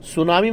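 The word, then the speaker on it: tsunami: सुनामी में क्या silent होता है? – टी.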